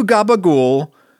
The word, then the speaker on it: gabagool: He says, hey, Paulie, I've got two gabagool, capicole, and prosciutto. – Mm-hmm. Yeah. – Gabagool is capicole. Gabagool is the, like, American-Italian famous weird pronunciation of capicola, which is the kind of, you know, salumi, right? – Right.